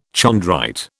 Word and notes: chondrite: British English

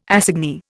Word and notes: assignee: Indian

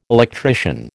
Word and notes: electrician: American English

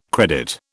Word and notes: credit: British English

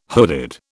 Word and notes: hooded: British English

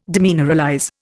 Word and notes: demineralize: Indian

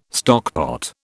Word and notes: stockpot: British English

